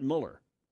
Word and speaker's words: Mueller: I said, who's Robert Mueller? He meant Mueller. – Yes. – His name is spelled Mueller, right? – Yes.